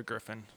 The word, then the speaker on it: griffon: Well, put the emphasis on whatever part you want, but a griffon is what I would say. Yeah, wire-hair pointing griffon. Yeah. – Griffon.